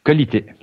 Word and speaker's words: Qualité: How do you say quality in French? Qualité? – Qualité.